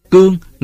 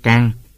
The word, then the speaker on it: Cương: Cương người Nam Bộ đọc là Cang.